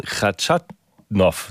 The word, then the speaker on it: Khachanov: Er trifft auf den Russen Khachanov, so ist es richtig.